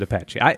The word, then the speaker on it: Apache: Well, and to be fair, there are plenty of English words where the E at the end isn't pronounced. So it's not totally obvious that you pronounce it Apache.